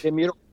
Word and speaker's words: Demirok: Murahem Demirok. – Demirok.